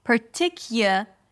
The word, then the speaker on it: particular: Then we shift to kia, kia, kia, a k sound, then y, y as in yes or yay, and a schwa, kia, kia. All right, no u sound there, although it's spelled with a u. Particular and then again, a reduced vowel, lur, lur, an L, and then you shift to an R, lur, as in learn, particular, and then you end it with li, li, high E at the end, particularly.